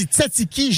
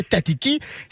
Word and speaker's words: tzatziki: La sauce tzatziki s'écrit avec les Z, fait qu'on fait, on dit tzatziki, jamais tatiki.